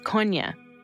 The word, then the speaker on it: Kony: The proper pronunciation is Konya.